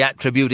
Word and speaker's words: attribute: He pronounced the word attribute with great relish, laying the accent on the first syllable. – I ask everyone, what did he attribute his health to?